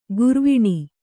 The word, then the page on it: ಗೌರೀವೀಣೆ: gaurī vīṇi